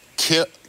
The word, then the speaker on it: kiln: Like this is kill, kill right? Kill. But it's spelled K I L N.